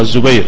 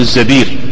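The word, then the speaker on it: الزَّبِير: هكذا ضبطه بعض الناس انطقها الزبير، هذا خطأ، بعبد الرحمن ابن الزبير،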